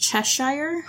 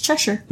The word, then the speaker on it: Cheshire: Which I've always pronounced Cheshire for whatever reason. But it's Cheshire.